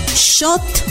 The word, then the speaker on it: xot: Para decir comal decimos shot. Shot se escribe X-O-T.